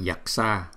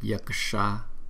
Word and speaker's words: Yaksha: Chúng ta đừng có đọc là Yaksha mà đọc là Yaksha Ka. Có chữ K nữa nhưng mà chúng ta đọc rất là yếu cho nên chúng ta thành thử là Yaksha, Yaksha, Yaksha, Rakshasa, Rak.